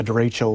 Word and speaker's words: derecho: What is a derecho? And did I pronounce that correctly? – Derecho, correct, yes.